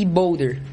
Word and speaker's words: boulder: mas a gente chama de lead e boulder, é assim que a gente pronuncia.